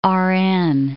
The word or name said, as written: RN